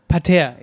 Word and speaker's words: Spatzeparterre: Spatzeparterre. Spatzeparterre. Ist es, äh, ist die Betonung auf Parterre oder ist es Pater? – Parterre, ja. ja.